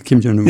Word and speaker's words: Kim Jong-un: They have a picture of him on a yacht once with Kim Jong-un. Did I get his name right? Kim Jong-un. – Yeah.